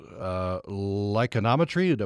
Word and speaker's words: lichenometry: You have a separate section on what you call your favorite thing, which is lichenometry. Am I pronouncing that correctly? – You are. Yeah, it just tickles me.